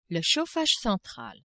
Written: chauffage central

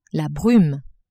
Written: brume

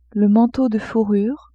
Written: manteau de fourrure

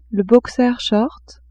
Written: boxer-short

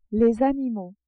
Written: animaux

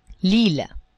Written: ile

